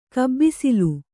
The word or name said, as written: ಕಬ್ಬಿಸಿಲು